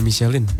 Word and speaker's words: Michelin: Ini Michelin, ban Michelin. Michelin itu bacanya Michelin.